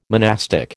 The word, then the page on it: monastic: American English